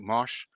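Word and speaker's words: Mauch: They had Cody Machin. Did I pronounce that right? Mosh? – Yeah, the kid from North Dakota State in.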